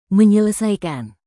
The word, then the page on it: menyelesaikan: Indonesian